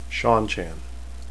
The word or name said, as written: seanchan